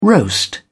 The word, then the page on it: roast: British English